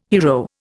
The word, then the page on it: hiero: Indian